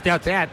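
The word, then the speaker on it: Tete: Por cierto, para confirmar pronunciación, Teate, ¿cómo es? – Yo creo que es Teat. – Yo creo que es Teat. – Teat. Correcto.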